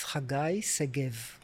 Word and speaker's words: Hagai Segev: My name is a Hebrew name, which is a bit difficult to pronounce, but it's Hagai Segev.